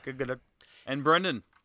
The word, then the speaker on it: Gadot: Gal Gadot is how Gal actually pronounces herself with a hard T on the end. Gadot.